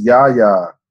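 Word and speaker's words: Yahya: For Watchmen, Yaha Abdul-Mateen. – It's Yaha. – Yaha, thank you.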